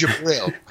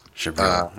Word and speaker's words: Jibril: It's Jibril. So the D and the J just kind of mush together. Okay. So you just say Jibril. – Jibril.